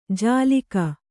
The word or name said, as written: ಜಾಲಿಕ